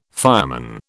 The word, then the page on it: fireman: British English